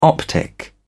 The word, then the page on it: optic: British English